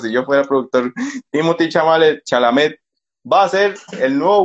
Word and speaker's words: Chalamet: Yo decía Chamalet y es Chalamet.